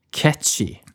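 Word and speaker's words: Cait Sith: Og uttales da catchy. catchy. Men for meg og alle andre så er det jo Kate Sith.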